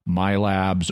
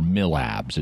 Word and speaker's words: MILABS: which is often abbreviated to either MILABS or MILABS. It's pronounced both ways.